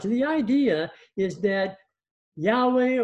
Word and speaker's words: Yahweh: Yahweh, as it's often pronounced.